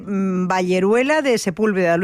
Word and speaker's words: Valleruela de Sepúlveda: Usted de Valleruela, de Sepúlveda, ¿lo he dicho bien? – Sí, vale. Valleruela de Sepúlveda, sí.